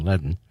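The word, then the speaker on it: Leadon: I think that's how you say his name, Ledden. It's like Led O-N.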